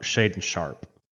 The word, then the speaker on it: Shaedon Sharpe: Shaedon Sharpe. I said the name right, didn't I? – Yeah. Yep. Yep.